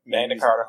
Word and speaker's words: Magna Carta: Yep, Magna Carta, Holy Grail.